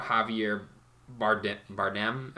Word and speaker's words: Javier Bardem: Because I had never known anything about Javier Bardem.